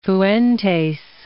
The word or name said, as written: Fuentes